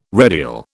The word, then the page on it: redial: British English